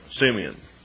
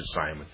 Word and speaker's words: Simeon: And she called his name Simeon. And you might want to pronounce it Simon.